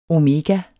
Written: omega

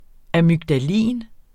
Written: amygdalin

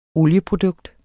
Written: olieprodukt

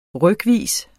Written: rykvis

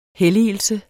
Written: helligelse

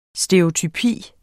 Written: stereotypi

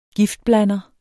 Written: giftblander

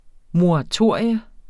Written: moratorium

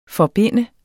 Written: forbinde

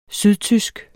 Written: sydtysk

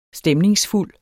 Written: STEMNINGSFULD

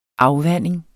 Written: afvanding

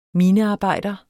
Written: minearbejder

